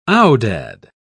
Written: aoudad

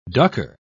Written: ducker

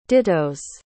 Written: dittos

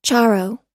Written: charro

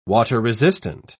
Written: water-resistant

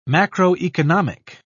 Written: macroeconomic

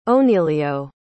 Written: onelio

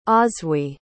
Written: oswy

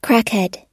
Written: crackhead

